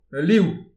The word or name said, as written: al liv